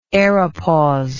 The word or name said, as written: aeropause